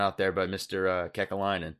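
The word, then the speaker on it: Kekäläinen: Oh, Adam's shaking his head at your pronunciation of Kekalainen, though. – Is it bad? Did I botch that? – No, you did. You did well.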